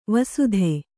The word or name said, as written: ವಸ್ತ